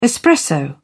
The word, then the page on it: espresso: British English